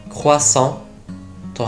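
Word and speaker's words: croissant: このクロワッサンフランス語ではクワッソンと発音しますが元をたどれば三日月という意味です三日月の形をしたパンダからクロワッサンというのですね。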